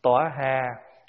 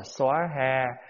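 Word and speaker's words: svāhā: Cho nên bài chú nào xong rồi cũng đều đọc sa va ha, là tùy theo chỗ phát âm. À, có chỗ thì phát âm là tóa ha, có chỗ là xóa ha, có chỗ là ta bà ha.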